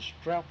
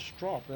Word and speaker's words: strop: Well, some people call it a strap, but I think it's properly strop, S-T-R-O-P. Well, it could be spelled S-T-R-A-P and pronounced S-T-R-O-P, one of the two, I don't know which.